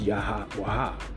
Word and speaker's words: Yahawaha: In the paleo tongue of the Zodiac app, it's Yahawaha. Yahawaha. That's how you say it in the paleo alphabet of the Zodiac app.